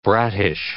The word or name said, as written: brattish